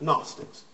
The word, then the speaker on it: Gnostics: It begins with a silent G, so it's a weird-looking word, but Gnostics.